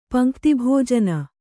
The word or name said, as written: ಪಂಕ್ತಿಭೋಜನ